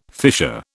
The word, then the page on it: fisher: British English